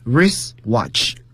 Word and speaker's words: wristwatch: wrist 表示手腕，但是读的时候 W 是不发音的，T 也不能发音。 wristwatch。